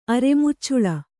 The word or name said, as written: ಅರೆಮುಚ್ಚುಳ